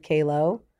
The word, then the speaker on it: Calo: No, there's two pronunciations. My family has different opinions about how to pronounce my name. – Because I always said Joanna Calo. – Yeah, that is like the actual Italian pronunciation. My brother pronounces it that way. Mm-hmm. But my dad, whose name it is, was like Americanized in Queens back in the day, and they said Calo.